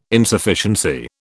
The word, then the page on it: Insufficiency: British English